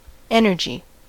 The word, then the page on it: energy: American English